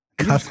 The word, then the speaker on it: Cus: Cuss, calls him Cuss.